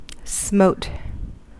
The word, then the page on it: smote: American English